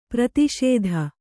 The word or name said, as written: ಪರಿತೋಷಿತ